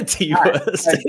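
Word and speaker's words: Ti West: By T. West, which looks really dope. – Ty West? – Oh, is that how you say it? – Yeah, T. West.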